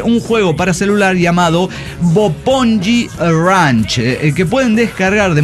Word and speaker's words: Bopongi: Bopongi Ranch. Pero luego dicen cómo lo pronuncias, no entiendo ni mergas. Bueno, B de burro, O-P-O-N-G-I.